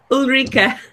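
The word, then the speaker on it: Ulrike: Ulrich, how do you say it? How do you say it? – Ulrika. – Yeah, Ulrika.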